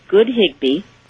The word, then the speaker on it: Good Higbee: It's Good Higbee.